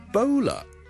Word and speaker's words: Ebola: 最近咧，如果大家有睇国际新闻咧，都应该会留意到 Ebola 呢个伊波拉病毒咧，喺某啲地方肆虐咁样。 Ebola 伊波拉病毒咧，我哋虽然广东话我哋译做伊。波拉波啦，我哋嘅 o 呢个发音，但如果系英文咧，我哋通常咧系读 ebola 嘅 ebola bow bow，好似弓箭嘅弓嗰个 bow，OK，、 okay?